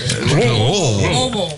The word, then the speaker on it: Louisville: Say it like you got a mouthful of marbles. Louisville.